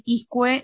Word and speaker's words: Izcue: Clarísimo, Maribel, Maribel Iscue.